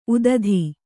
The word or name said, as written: ಉತ್ತುತೆ